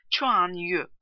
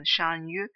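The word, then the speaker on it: Chuan Yu (單于): Hunlarda hükümdarlık ünvanı olarak San Yu, orijinal okunuşu Chuan Yu kullanılmaktadır. Sonsuz yüce genişlik anlamlarına geldiği şeklinde fikirler olsa da kesin sonuca varmak imkansızdır. Burada kelimenin Türkçe okunuşu olan Shan Yu tercih edilmiştir.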